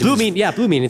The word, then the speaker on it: Blumine: Well, correct me if I'm wrong in the pronunciation, Blumina, I'm not quite sure. – Yeah. – But in any case, Blumine, it sounds right.